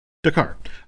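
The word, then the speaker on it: D'Qar: Qatar is, am I saying that right? – Dakar. – Yes.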